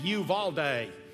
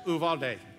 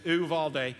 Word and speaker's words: Uvalde: Now, let me just stop and say, here in Georgia, the way we say it is Uvalde, because we add syllables to things around here. But the way they say it there is Uvalde. So not you, right? Just Uvalde.